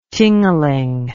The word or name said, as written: tingaling